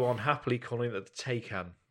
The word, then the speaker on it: Taycan: So T-A-Y-C-A-N is apparently pronounced Taycan. – As it Ty as in fighter, Khan as in Khan!